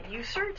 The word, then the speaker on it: USERT: Okay, this is for USERT. Is that the best way to pronounce it? – Yeah.